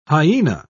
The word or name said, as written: hyena